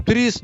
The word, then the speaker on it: ТРИЗ: Но э, под ТРИС педагогикой мы так и произносим. ТРИС, дефис педагогика.